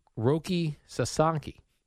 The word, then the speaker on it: Roki Sasaki: Japanese pitcher Roki Sasaki.